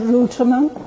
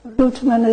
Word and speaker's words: Ruiterman: My name is Anne Ruteman. I live 11 Enclosure. Ruteman is spelled R-U-I-T-E-R-M-A-N.